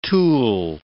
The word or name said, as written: tulle